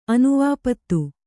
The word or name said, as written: ಅನುವಾಪತ್ತು